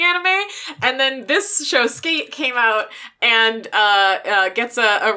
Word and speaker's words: SK8: Okay, we are going to watch the first episode of Skate, which is spelled SK8, the number 8, which is an anime about skateboarding.